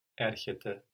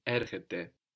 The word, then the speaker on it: erchete: So we have the e on the end spelled like ai, like it is in ine. We also have a t now in erchete, like ton, tin, to. So that can help us.